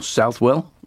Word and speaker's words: Southwell: Next one is Gate to Southwell, or Southwell, as it's spelled.